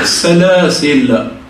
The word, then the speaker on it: السلاسل: والسلاسل معروفة يعني كما نسموها احنا السناسل، احنا ننطقها بالنون ولكنها في اللغة العربية بالله السلاسل، إذ الأغلال في أعناقهم والسلاسل يسحبون.